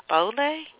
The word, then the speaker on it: Baoulé: and the Baoulé and Bago. Is that how you pronounce it? – Yes, that's fine. That's fine.